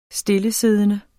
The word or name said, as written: stillesiddende